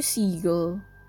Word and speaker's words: Siegel: Alam ko Siegel. Parang Siegel, ganun.